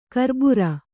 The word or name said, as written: ಕರ್ಬುರ